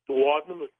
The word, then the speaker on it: duodenum: And he found out it was because of the duodenum. Am I pronouncing that correctly? Or duodenum? – Duodenum.